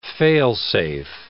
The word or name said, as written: fail-safe